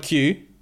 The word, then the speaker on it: queue: I was today years old when I found out that Q, the word Q, is just okay. So Q U E U E, is just a Q, the letter followed by four silent letters.